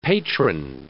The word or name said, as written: patron